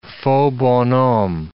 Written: faux bonhomme

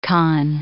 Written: khan

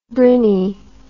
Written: brinny

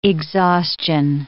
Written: exhaustion

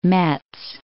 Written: Metz

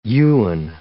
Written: yuan

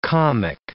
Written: comic

